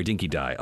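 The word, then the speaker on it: Dinky Di: But the we actually did have in the first couple of years we had a dinky D dog food eating contest. – Hopefully it wasn't Chinese dog food. – No, sorry, Dinky Dai. Oh, I can't believe I pronounced it wrong.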